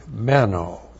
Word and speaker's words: meno: You know now that John has a word, meno, M-E-N, long O, usually translated abide.